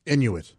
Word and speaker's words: Inuit: Is it pronounced Inuit or Inuit? – Inuit.